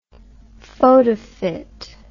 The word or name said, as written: photofit